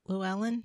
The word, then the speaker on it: Llewellyn: It's actually by your publisher, Llewellyn. Is that how you say it? Mm-hmm.